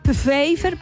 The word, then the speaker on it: Pfeiffer: Pfeiffer, es que tiene una P antes. Pfeiffer, como Michelle Pfeiffer. Sí, perdón, perdón. Ella es enfermera de logística, grande, única y nuestra de logística, del Hospital Militar. Pamela Pfeiffer, como Michelle Pfeiffer, tiene el mismo apellido, similar, con una P.